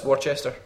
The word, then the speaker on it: Worcester: So, and what about CJ from Worcester? – Worcester, it's called. But anyway, go. – No, it's Worcester. – It's Worcester, as in Worcester sauce.